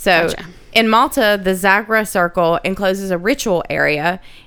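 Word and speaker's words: Xagħra: In Malta, the Zagra Circle, that's spelled with an X. X-A-G-H-R-A. – Where's Malta? – Malta is a island off the coast.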